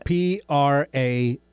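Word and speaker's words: Pasricha: Neil Prasricha. – Which is Prasricha.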